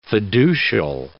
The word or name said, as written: fiducial